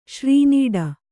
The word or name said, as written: ಶ್ರೀನೀಡ